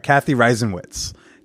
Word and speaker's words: Cathy Reisenwitz: With writer and part-time hoe and activist, Cathy Reisenwitz.